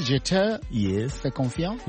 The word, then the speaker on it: Je te fais confiance: My French is not too good, but is it Je te fais confiance? – Wow.